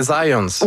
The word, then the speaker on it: Zajonc: Non, c'est Zajonc. – Ouais? OK. – Ouais, j'ai-tu pas dit Zajonc? – Ben, j'avais dit plus Zajonc, mais. – Ah, ben, j'ai prononcé un petit peu à l'anglais. J'ai prononcé mon polonais à l'anglais.